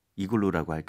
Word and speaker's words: igloo: 자, 마지막으로 이글루를 영어로도 이글루라고 할까요? – 네. 근데 영어로는 이음절로 발음하셔야 돼요. 그래서 이글루가 아니고 이글루. 이글루. – 이글루. 그렇죠? I-G-L-O-O 되겠습니다.